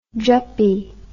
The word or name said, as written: juppie